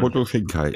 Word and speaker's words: Makoto Shinkai: Man soll vielleicht noch dazu sagen, es gibt noch einen Roman zum Film, der auch vom, vom Autor äh, Makoto Shinkai. Spricht man den so aus? Du weißt es wahrscheinlich. Makoto Shinkai, ja, ja genau.